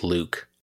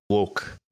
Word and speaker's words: LEWCKEE: Don't tell me it's Luke. – So that's good. It's actually pronounced like look, look. Like I'm looking for something. – Look. – It's look. So LEWCKEE, it's pronounced look.